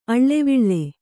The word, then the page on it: ಅಳ್ಳೆವಿಳ್ಳೆ: aḷḷeviḷḷe